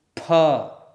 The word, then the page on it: ph: sanskrit